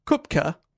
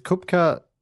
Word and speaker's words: Koepcke: Is Kupka pronounced cupcake in Australia? – No, it's actually spelled K-O-E-P-C-K-E. And if I'm pronouncing that wrong, blame the guy on YouTube I watch pronounce it.